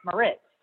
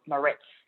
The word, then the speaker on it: Mariz: And yeah, because sometimes they would add a T and they would call me Maritz.